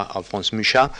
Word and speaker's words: Alphonse Mucha: encabezados todos por el famoso Alphonse Mucha, pronunciado a la francesa Alphonse Mucha, que vive un tiempo en París y luego eh, se vuelve protagonista del modernismo en Checoslovaquia.